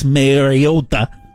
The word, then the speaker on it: Mariota: If you're thinking of betting that Marcus Mariota, the Italian pronunciation.